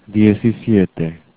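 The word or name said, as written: diecisiete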